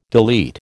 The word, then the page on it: delete: American English